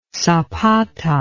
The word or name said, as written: zapata